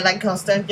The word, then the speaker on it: Lancaster: Yeah, Lancaster, yeah.